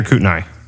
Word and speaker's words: Kootenai: Is it Kootenai or Kootenai? – Kootenai. – Kootenai. That's not how it's spelled.